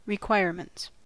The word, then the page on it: requirements: American English